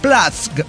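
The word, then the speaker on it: płaszcz: Plask, lo estoy pronunciando. – Póngale. – Polacola, ¿no? Platz, pero aquí el chiste es deletrearle cómo claro. – Se escribe. Es P L A S Z C Z.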